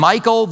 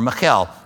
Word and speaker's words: Michal: And Michal, the daughter of Saul, came out to meet him, or Michal. We call her Michal. Michal. Michal is the Hebrew name. Michal is an easier way for us to pronounce it.